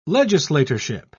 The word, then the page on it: legislatorship: British English